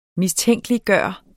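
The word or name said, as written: MISTÆNKELIGGJORTE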